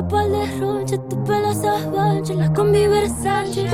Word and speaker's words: La Combi Versace: C'était La Combi Versace de Rosalía. Et oui, j'ai réussi à le prononcer.